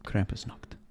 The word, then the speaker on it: Krampusnacht: Yes, that is that how you say it? Krampusnacht? – Krampusnacht.